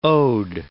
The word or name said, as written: ode